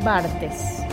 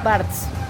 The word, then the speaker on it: Barthes: Porque argentinizado es Barts. – No, eso es yanquilizado. – Bueno, no sé. Se está pronunciando con una lógica yanqui, imperialista.